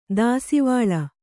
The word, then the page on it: ದಾಸಿವಾಳ: dāsivāḷa